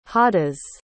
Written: hadas

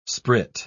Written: sprit

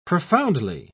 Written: profoundly

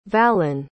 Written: vallen

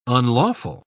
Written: unlawful

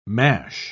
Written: mash

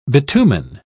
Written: bitumen

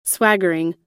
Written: swaggering

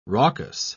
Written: raucous

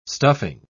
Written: stuffing

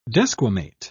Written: desquamate

